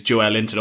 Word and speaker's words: Joelinton: I don't know a word of Portuguese, but apparently the correct way to say it is Joel Linton, or at least a mark along that.